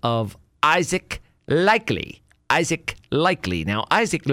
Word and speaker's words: Likekele: He does not spell it L-I-K-E-L-Y. He spells it L-I-K-E-K-E-L-E. Likely is kind of how it looks, but it's pronounced likely.